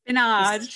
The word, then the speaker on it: spinnage: Spinach. Spinach. – So it's spelled S P I N N A G E. Yeah. Spinach. And I would really like to know if anyone else has ever heard of this.